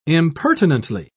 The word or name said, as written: impertinently